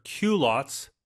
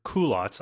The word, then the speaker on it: culottes: The pronunciation, I've heard culottes, but I've also heard culottes. I tend to go with the latter, culottes, but it comes from the French, right? It's a French word.